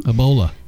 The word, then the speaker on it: Ebola: You know, I was just reading that article about the Ebola. Am I saying it right? – Ebola.